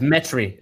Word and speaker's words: Metry: It's the first one. It's metry. It's like geometry, but without the geo.